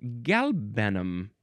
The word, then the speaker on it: galbanum: Of Semitic origin, akin to the Hebrew word helbinach, which means galbanum.